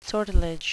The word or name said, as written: sortilege